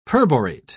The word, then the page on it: perborate: British English